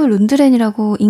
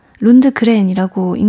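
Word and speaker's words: Lundgren: 근데 이게 구스타브 룬드렌이라고 읽는 경우도 있고 구스타브 룬드그렌이라고 읽는 경우도 있더라고요. 검색을 하실 때 참고를 하시면 좋을 것 같고요.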